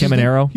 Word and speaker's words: Caminero: I mean, it's not like the guy from who's here tonight, Camarino. Is that how you pronounce Caminero? – His name?